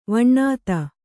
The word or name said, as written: ವಿನೋದಿ